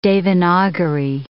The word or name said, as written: Devanagari